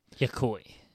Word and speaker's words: Yokoi: The Game Boy, the invention of a guy at Nintendo named Gunpei Yokoi, I believe is how it's pronounced. – Yokoi.